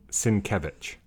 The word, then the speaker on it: Sienkiewicz: Say it again. – Sienkiewicz. – Sienkiewicz. – I'm not quite sure where he places the emphasis, but those are the three Sienkiewicz.